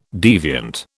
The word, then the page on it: deviant: British English